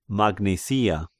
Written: Magnesia

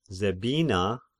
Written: Zebina

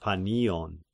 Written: Panion

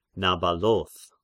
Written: Nabaloth

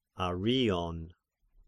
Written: Arion

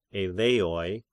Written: Eleans

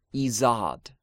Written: Izad

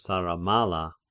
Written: Saramalla

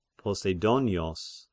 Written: Poseidonios

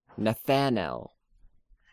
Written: Nethanel

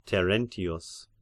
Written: Terentius

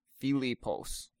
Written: Philippos

